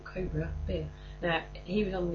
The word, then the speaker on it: Cobra: and he pronounces it Cobra. Because we've always found it a strange phenomenon. If you talk about a snake, you say Cobra. But if you're in a restaurant, you say Cobra. But that's not how it's said.